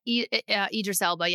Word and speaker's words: Idris Elba: Yeah, Idris Elba.